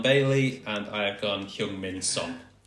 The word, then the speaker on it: Heung-Min Son: Why was Cresswell such a stretch, but perfect Korean pronunciation of Heung-Min Son? – Cresswell and Heung-min Son. Yeah, well done. – Said perfectly. – That's fantastic. – A perfect Korean accent.